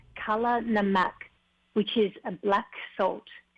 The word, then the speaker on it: kala namak: It's called kala namak. It's spelled K-A-L-A-N-A-M-A-K. Kala namak.